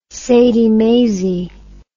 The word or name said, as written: sadie maisie